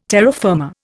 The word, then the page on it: terra firma: Indian